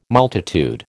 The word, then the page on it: multitude: American English